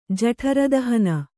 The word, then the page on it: ಜಠರದಹನ: Kannada